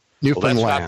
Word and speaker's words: Newfoundland: Newfoundland. – Well, that's not the way they say it. – The emphasis is on the land.